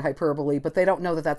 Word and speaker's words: hyperbole: It's pronounced hyperbole, H-Y-P-E-R-B-O-L-E.